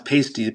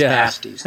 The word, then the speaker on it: pasties: When you read it, you think it's pronounced pasties, but they'll correct you and say it's pasties.